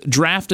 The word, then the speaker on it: Draught: Draft is spelled like the old school traditional draft beer was spelled. Don't ask me why. D-R-A-U-G-H-T.